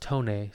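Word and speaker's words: Tonex: This next one I got is Tone, pronounced Tone. So it's T-O-N-E-X. In the bottom it says pronounced Tone.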